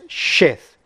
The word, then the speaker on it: Sheth: and if one points it with a dot on the right side, as it is here, this Hebrew letter is called Shin, and pronounced Sh, and hence Sheth.